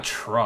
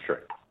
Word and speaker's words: Tra: It was spelled like T-R-A, remember, because I brought it up during the game. Yeah, but you don't pronounce that Tra Carson.